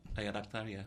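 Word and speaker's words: Ayad Akhtar: Ayan Akhtar, yeah.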